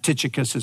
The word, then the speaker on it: Tychicus: I think for us who are just reading strictly from English, that Tychicus is probably the easiest way to pronounce here.